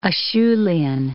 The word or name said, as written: Acheulean